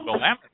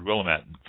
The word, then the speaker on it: Willamette: Yeah, no, that's the way we pronounce it in this area. – Ah, okay. – It's like you say tomato, I say tomato, I guess. – Yeah. – But I just struck me as strange because I've always heard Willamette, so the accent on the end.